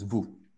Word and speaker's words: vous: So for example, typically, vous, which is vous. So you get no S, okay? And the OU become OU, vous.